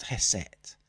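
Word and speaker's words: ḥst: But it's interesting, the word for music in ancient Egyptian is spelt, in our words, HST, but it's pronounced Heset.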